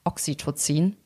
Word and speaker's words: Oxytocin: Ähm, und es gibt dann noch andere Hormone, die mit reinspielen, also Adrenalin oder das Kuschelhormon Oxytocin.